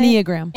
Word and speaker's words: Enneagram: And I think the Enneagram is, I'm probably saying it wrong. And how do you say Enneagram. – It? Enneagram. – Yep.